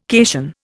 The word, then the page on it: cation: Indian